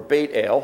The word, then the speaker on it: Beit El: Bethel, or Beit El, to pronounce it correctly.